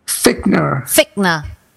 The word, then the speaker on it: Fichtner: Fichtner.